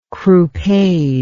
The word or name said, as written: croupade